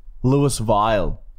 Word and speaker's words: Louisville: It's not Louisville, is it? – Where Dorothy's from. – Louisville. – Wizard of Oz. Like, she was from there. – Lewis Vile is pronounced here in Australia. Lewis Vile, KY.